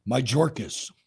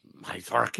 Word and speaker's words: Mayorkas: Mayorkas. – Mayorkas. – First name. – Alejandro. You know what? Speaking of him, real quick, before I forget. I saw something this morning that Mayorkas personally refused RFK Jr. Secret Service.